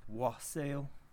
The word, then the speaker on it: wassail: which is pronounced wassail and spelled W-A-S-S-A-I-L.